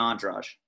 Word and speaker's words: Andrade: If you guys, you know, pay attention to that, that's Andrade and how do you say her last name? Rose Namajunas? – Namajunas and Andraj.